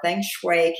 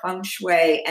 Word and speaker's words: feng shui: Now, this word, you may have heard it before, feng shui can be pronounced that way or feng shui, and both are considered correct.